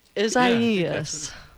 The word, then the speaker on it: Isaias: I phonetically wrote it down as Isaias. – Isaias. Good job.